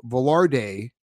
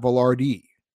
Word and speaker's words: Vilardi: Apparently I was saying Velarde and not Vilardi.